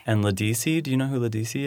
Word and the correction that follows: Ledisi: Oh, Ledisi. – Oh, is that how you say it?